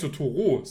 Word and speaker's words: Totoro: Ich sag Totoru, der heißt Totoro.